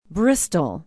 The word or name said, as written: bristol